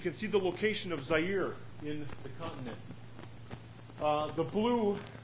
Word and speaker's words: Zaire: You say it, and it comes out Zaire, and that's how you pronounce it.